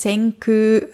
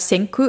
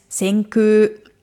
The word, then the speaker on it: Senku: Senku, na, Vorsicht, die Aussprache ist Senku, also ein langes U. Also nicht so, wie man schreibt Senku, also das K und U, sondern eigentlich ist Senku.